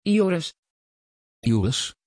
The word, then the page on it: Yoris: Dutch